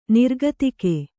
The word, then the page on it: ನಿರ್ಗತಿಕ: nirgatika